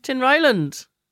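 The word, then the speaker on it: Tinryland: Do you like my pronunciation of Tin Ryland? – Yeah, you have it spot on.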